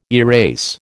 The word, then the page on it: erase: American English